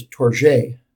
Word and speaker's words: Tourgée: How do I pronounce his name? – I usually have heard it as Tourgée. – Tourgée and Elizabeth Cady Stanton.